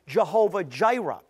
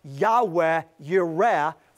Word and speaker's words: Yahweh Yireh: They mispronounce it and call him Jehovah Jireh. When the church calls God Jehovah Jireh, that is the mispronunciation of Yahweh Yireh from Genesis 22:14.